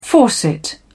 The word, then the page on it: faucet: British English